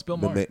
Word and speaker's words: Maher: I wanted to talk about Bill Maher. How do you even say his name? – Since we're talking about food. No, it's Bill Maher.